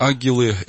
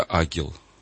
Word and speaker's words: ангел: А традиция славянская сохранила такое интересное свойство, что если речь идет о добром ангеле, то читается правильно ангел. А если о бесе, то тогда агел. Агил, то есть Г читается дважды.